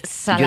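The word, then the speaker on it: Szalai: Pues me traje un libro que se llama La carne, que es de David Snars. Ahora pronunciaré bien su nombre. David Salai.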